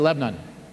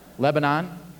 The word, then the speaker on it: Lebanon: Or you say Lebanon? Lebanon? Oh, you pronounce the whole thing, unlike in Pennsylvania.